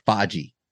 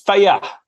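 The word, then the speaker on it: Fage: I thought it was like faji. – yeah, it's uh, it's it's faya. I think is, is how you pronounce it.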